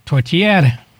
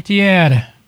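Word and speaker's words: tourtière: Tortiere. – Ah, that's how you pronounce it. Okay. – Tortiere.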